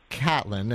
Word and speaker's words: Caitlin: So your name, which is spelt like Caitlin but pronounced Catlin, and there's a story behind that, right? – Yes.